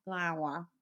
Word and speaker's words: Lyon: How do you say Lyon? Lyon. Lyon, yeah.